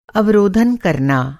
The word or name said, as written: अवरोधन